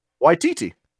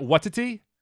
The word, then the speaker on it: Waititi: How do you pronounce his last name? I've been told that I'm wrong. Waititi. – Isn't it Watiti? – No, it's definitely not.